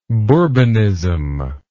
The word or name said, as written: bourbonist